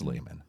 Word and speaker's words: Lehmann: Well, in layman's terms, mm-hmm. It's Lehmann.